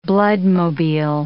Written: bloodmobile

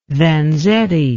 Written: Vanzetti

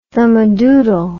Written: thumadoodle

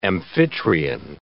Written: Amphitryon

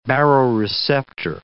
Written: baroreceptor

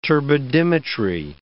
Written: turbidimetry

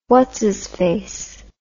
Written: whatsisface

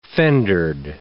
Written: fendered

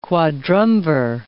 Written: quadrumvir

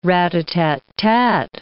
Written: rat-a-tat-tat

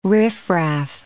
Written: riffraff

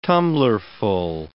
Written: tumblerful

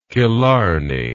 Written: killarney

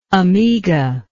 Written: amiga

